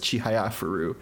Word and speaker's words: Chihayafuru: It's sometimes I say Chihiafru, which is again one syllable affecting the next, but it's Chihiafru, and that's the first line of a poem.